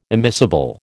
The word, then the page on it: immiscible: American English